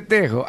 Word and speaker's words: tejo: No se dice Tejín, se dice Tejo.